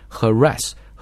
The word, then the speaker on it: harass: 注意读这个单词的时候，它的这个重音啊落在这个 ra 上面， harass harassment、啊、那么具体这个怎么读啊，还有包括语音怎么提升，大家呢可以订购我们在喜马拉雅上的这个暴虐晨读版的课程，会讲的更加的细致啊，就在讲这个语音。